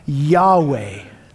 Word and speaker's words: Yahweh: No, transliterated to English, this is pronounced Yahweh, not Lord, which is the same root word as I am, what came just before in verse 14.